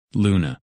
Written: Luna

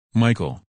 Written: Michael

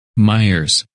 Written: Myers

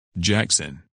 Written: Jackson

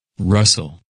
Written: Russell

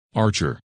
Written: Archer

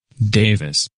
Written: Davis

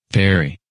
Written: Perry

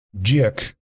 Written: Jack

